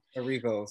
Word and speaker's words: Erivo: I'm gonna pronounce her last name wrong, and Rio. Um, Erivo, Cynthia Erivo.